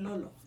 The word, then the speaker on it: Lolo: Lola and Lolo.